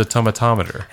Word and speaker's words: Tomatometer: It can't be thermometer, because it's there's an extra syllable in there. – Tomato. – It's spelled tomato and then meter. – Oh. – So I always thought it was the tomatometer.